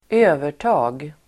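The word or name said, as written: övertag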